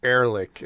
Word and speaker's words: Erlich: Erlich is his name. Erlich. – Yeah, it's spelled E-R and then Lick.